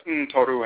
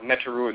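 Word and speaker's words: Ntoro: We talk about the nature and the function of the Abosom, the Orisha, the Vodou, the Ntoru and Ntorotu, sometimes mispronounced Neturu and Netutu,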